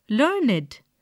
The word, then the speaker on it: learned: We pronounce it as learned, to rhyme with created, waited.